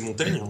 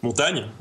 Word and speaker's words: Montaigne: Euh, j'imagine que vous connaissez Montaigne, dont le nom se prononce en vérité Montaigne, parce que c'est une mauvaise lecture d'un digramme. J'en parle de ma vidéo sur les formes d'orthographe, mais Montagne, Montaigne,